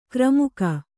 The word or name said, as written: ಕಾರ್ಮುಕ